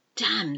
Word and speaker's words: damn: C-O-L-U-M-N. A dam. Damn. Damn this. Oh dear. Damn. It's not a good word, but you don't pronounce the N.